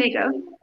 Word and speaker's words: Chernigov: Chernigov.